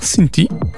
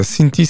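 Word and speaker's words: Cinthie: Cinti.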